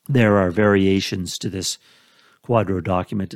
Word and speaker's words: QDRO: The initials are QDRO, and it's pronounced Quadro.